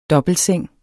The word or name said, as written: DOBBELTSENGEN